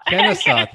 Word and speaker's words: Kennesaw: Is it Kennesaw? How am I saying that? – Kennesaw. – Thank you. Kennesaw State University, bachelor's in international relations and affairs.